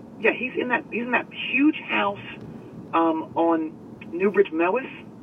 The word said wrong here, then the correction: Mews: M-E-W-S. – Mews? – Is that how you say it? – Newbridge M-E-W-S. Mews. – I've been saying it Mewis for my entire life. – Well, it's Mews.